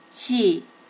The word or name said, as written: shi